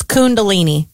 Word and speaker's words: Kundalini: Is it a con or a kun? – Well, it's K-U-N. – K-U-N. Kundalini. – Well, that doesn't answer my question. Is it? – It's Kundalini. – So it's pronounced like it's an O-O-O-O.